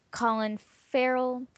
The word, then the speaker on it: Colin Farrell: Well, other peoples in this film is Colin Farrell.